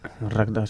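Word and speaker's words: Rakta: Han formado pues y, o forman parte de otros proyectos, como han podido ser Las Otras, Ciega, Invisibles o Razca. – Racta, sí. – Racta. Joder, siempre lo digo mal.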